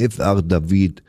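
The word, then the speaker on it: F. R. David: Ja, der wird tatsächlich nicht F.R. David ausgesprochen, der Mann, der jetzt kommt, sondern der hat erstmal gesagt selber, hello, I'm F.R. David.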